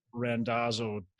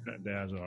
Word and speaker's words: Randazzo: Did I say your last name right? I asked you before, and now I feel like I just mispronounced it. You got it. You got it correct. It's Randazzo.